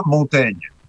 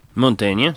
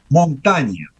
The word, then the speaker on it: Montaigne: Pero en el francés del siglo XVI, la I, que genera ese sonido en francés de E, una E abierta, cumplía otra función fonética y se pronunciaba montaña.